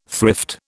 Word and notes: thrift: British English

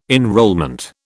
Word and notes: enrolment: British English

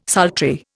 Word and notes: sultry: Indian

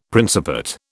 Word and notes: Principate: British English

